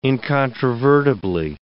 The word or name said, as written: incontrovertibly